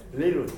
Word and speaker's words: little: One little girl、これ繰り返しなんだけど、リトルって言わなくていいですから、リトル、リトル、リトル、リトルって言おうとしたらどうしてもそこでリトルってなっちゃうんで、 One little girl、あの T の発音と L の発音の下の位置がほぼ同じなんだそうです。ちょっとル、トル、同じなんで、リルって感じでいいということなので、ワンリル、ジャストいきます。